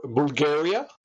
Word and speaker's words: Bulgari: Has nothing to do with the country Bulgaria. Nothing. Nothing whatsoever. Okay. But if you want to pronounce it in the original name, it's Bulgari in Italian.